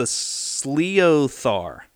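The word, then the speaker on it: sliotar: It's the Sleothar. Sliothar. Sliothar. Sliothar. – I feel like Gaelic, I'm going to say Sliothar just because. – Makes sense. – I feel like they put emphasis on the I in there.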